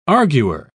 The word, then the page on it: arguer: British English